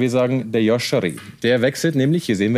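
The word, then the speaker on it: Ardon Jashari: Heute ist der große Yashari. So spricht man ihn aus, oder? – Ich sag Ardon Yashari. Ich glaube, der Schweizer würde es so sagen.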